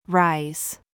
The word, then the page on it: ZOOS: American English